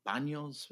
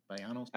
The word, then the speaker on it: Baianos: This band called, I don't know how to say it, Novos Baños? It's not bathroom, but it's like B-A-I, ANOS. Baianos.